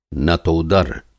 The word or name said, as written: नतोदर